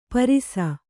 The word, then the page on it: ಪರಿಸು: parisu